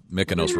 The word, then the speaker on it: Mykonos: Yeah, Mykonos rhymes with agnostic.